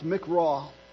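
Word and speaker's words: mikra: The word for convocation is pronounced mikraw, and it means a summoning or an assembly.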